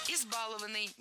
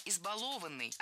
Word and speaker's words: избалованный: Избалованный. Неверно. Правильно. Избалованный. Опять же, ударение на корень не ставим. Избаловать. Избалованный. И на этом пришло время прощаться.